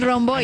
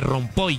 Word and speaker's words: Romboy: ¿Rompoy o Romboy? – Bueno, ¿cómo se dice ahí? – El Romboy. – Algunos dicen disque Rompoy, yo no sé de dónde sacaron.